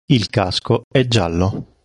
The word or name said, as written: giallo